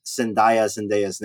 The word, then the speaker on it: Zendaya: How do you pronounce Zendaya's name? I think it's different in every country. – Yes, Zoe Zendaya.